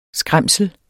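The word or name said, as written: SKRÆMSLET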